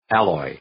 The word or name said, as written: alloy